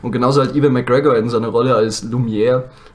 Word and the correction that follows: Lumière: oder wie man das ausspricht. – Lumière. genau so spricht man es aus, da hört ihr es.